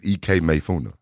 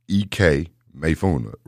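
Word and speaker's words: Ikemefuna: All right, I-K-E-M-E-F-U-N-A, E-K Mayfuna, right? So, you know, if I spell E-K how it's spelled, is I-K-E, right? But if you see I-K-E on the paper, you're going to think Ike. – Right. – Or Ike. – You see what I'm saying? – Yeah. – So if I say E-K, yo, you know, E-K. Sonically, it's E-K.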